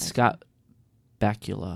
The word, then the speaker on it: Scott Bakula: That's and Scott Bakula. Is that how you say his name? – Uh-huh.